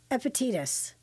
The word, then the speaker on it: Epictetus: That's Epictetus.